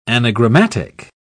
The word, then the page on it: anagrammatic: British English